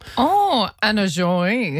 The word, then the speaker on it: Joie: I assume it's pronounced Joey, J-O-I-E.